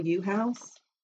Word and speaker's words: Uhouse: And according to one of the people who came forward, his name is Bill Uhouse, said, and that's how I'm going to pronounce it, is literally spelled U-H-O-U-S-E.